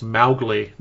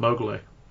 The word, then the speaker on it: Mowgli: the name should be pronounced Mowgli, not Mowgli.